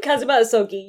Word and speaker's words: Kazuma Asogi: So, your best friend, Kazuma Asogi, Asogi? Asogi? How do you, how does one pronounce Dead Boy's name? Kazuma Asogi, you're right.